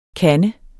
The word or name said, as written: KANDERS